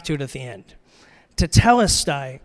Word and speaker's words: tetelestai: Pronounce it this way tetelestai. So let's practice together. Tetelestai. Okay, ready? One, two, three.